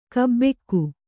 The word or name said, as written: ಗಬ್ಬಿಗೆ